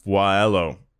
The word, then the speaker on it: Voiello: Maybe his most famous non-automotive design was an edible one, the Maria pasta shape that he developed for pasta maker Voilello. I don't even know how to say it. V-O-I-E-L-L-O. Voilello.